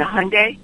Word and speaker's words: Hyundai: Well, I remember in probably 86, maybe, when the Hyundai first came over, and you couldn't get it in all states. Then nobody could pronounce it, and I think their advertising slogan was rhymes with Sunday, as in ice cream, because nobody knew how to pronounce it.